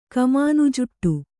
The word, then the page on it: ಕಮಾನುಜುಟ್ಟು: Kannada